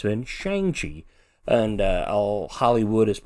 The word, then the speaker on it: Shang-Chi: That's kind of his post that he posted during Asian Heritage Month, how it's actually supposed to be pronounced. Um, he also pronounced how the name is supposed to be said, which he says Shang-Chi.